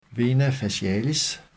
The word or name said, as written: v. facialis